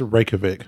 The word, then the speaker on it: Reykjavik: Now, I am pronouncing all this correctly, but the capital of Iceland, Reykjavik, has a penis museum. – I think it's Reykjavik. – That's what I said.